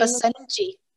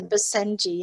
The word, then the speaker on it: Basenji: The name Basenji. It's a Basenji. Yeah. – Basenji.